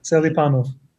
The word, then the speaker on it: Selipanov: How do you say it? – Selipanov. – Selipanov. – Yeah, that's not too far off.